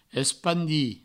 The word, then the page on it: espandir: provençal; espandi